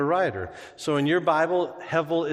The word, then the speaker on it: hevel: The word is hevel. It's spelled H-E-V-E-L. Hevel. Repeat after me.